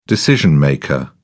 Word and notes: decision maker: British English

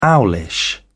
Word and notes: owlish: British English

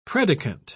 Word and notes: predicant: British English

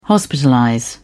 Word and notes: hospitalize: British English

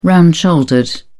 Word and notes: round shouldered: British English

